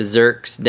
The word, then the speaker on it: Zerk: Make sure you wipe the Zerks down first, and it's Zerk, Z E R K. I see everybody mispronouncing it and calling it every other thing under the sun. Um, it's a Zerk.